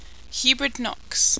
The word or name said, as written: hubrid nox